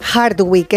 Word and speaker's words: Hardwick: Hardwick, eso es. Se escribe, bueno, un poquito como suena, H-A-R-D-W-I-C-K, ¿no?